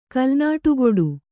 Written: ಕಲ್ನಾಡುಗೊಡು

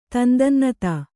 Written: ತಂದನ್ನತಾ

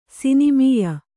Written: ಸಿನಿಮೀಯ

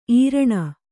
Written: ಈರಣ

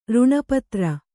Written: ಋಣಪತ್ರ

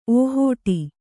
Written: ಓಹಟಿ

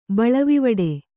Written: ಬಳವಿವಡೆ

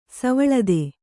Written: ಸವಲತ್ತು

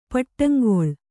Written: ಪಟ್ಟಂಗೊಳ್

